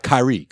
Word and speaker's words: Kyrie: No. Kyrie. That's how you say it.